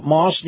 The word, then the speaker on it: Maus: And it's amazing how Moss Nissan, that's spelled M-A-U-S,